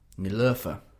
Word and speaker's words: Niloufer: How would you pronounce that? N-I-L-U with an umlaut, F-E-R. – Niloufer. – Niloufer.